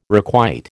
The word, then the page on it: requite: American English